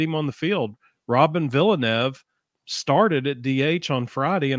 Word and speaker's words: Villeneuve: So it's Villeneuve. It is not Villanueva.